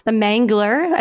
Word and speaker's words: The Mangler: The Mangler, as in like the strangler, rhymes with the mangler? – Correct, yeah.